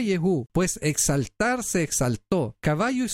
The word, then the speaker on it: Yehú: De hecho, Yehú debe de haberse pronunciado Yehuja, porque tiene una hey al final.